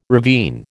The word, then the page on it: ravine: American English